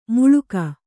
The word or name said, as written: ಮುಳುಗು